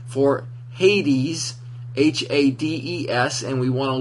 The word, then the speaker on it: Hades: Hades. – Hades.